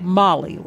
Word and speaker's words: MOLLE: And it's pronounced MOLLE, like the name MOLLE.